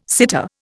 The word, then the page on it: sitter: Indian